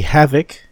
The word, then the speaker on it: Havic: Let me make sure I say it right here. The Havik, HAVIC, HVMS745.